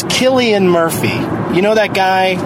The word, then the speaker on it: Cillian: It's pronounced Cillian. – Yeah. And some people got so mad at me on some message board just screaming about how, get it straight, it's Cillian.